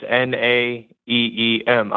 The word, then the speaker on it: Naeem: It's N A E E M.